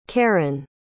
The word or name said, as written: kerryn